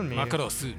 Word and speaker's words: Macross: Macross, la pronuncié bien, ¿no? – Macross. – Macross, sí.